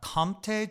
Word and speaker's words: Comté: It was a scramble, eggs, Comté cheese, is that how you say it? – Uh-huh.